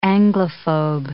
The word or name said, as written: Anglophobe